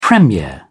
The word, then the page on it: premier: British English